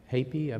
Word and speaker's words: Hapi: So there was a god of the Nile that was Hapi, I believe is the right pronunciation.